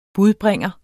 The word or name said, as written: budbringer